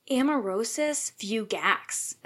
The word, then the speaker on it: amaurosis fugax: Quick correction, I just looked it up. It's amaurosis fugax. That is how you pronounce that.